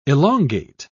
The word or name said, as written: elongate